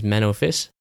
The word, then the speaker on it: Menno Vis: He is Manofis.